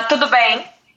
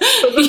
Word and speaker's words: Tudo bem: Tudo bem. – Tudo bem? Did I say that right? – Yeah. – Did I put the accent on there properly? – Yeah. Perfect. Tudo bem.